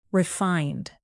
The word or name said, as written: refined